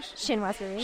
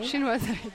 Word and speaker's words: Chinoiserie: Chinoiserie. – Chinoiserie, thank you.